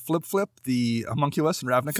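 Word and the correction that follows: Fblthp: Fibble Flip? Did you mispronounce Fibble Flip? – I did. I'm sorry. The lack of vowels threw me off.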